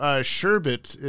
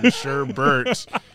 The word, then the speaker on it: sherbet: So Sherbet is the correct pronunciation. Sherbert is just incorrect pronunciation.